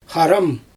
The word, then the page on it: xaram: Xyzyl